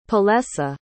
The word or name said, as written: palesa